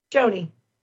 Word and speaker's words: Joni: So it's J-O-N-I, which is pronounced? – Joni. – Joni. It would be like Joni without the A.